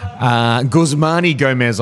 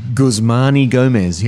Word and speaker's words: Guzmán y Gómez: Guzmán y Gómez, I believe it's pronounced, Alan. – Guzmán? – Guzmán y Gómez, yeah.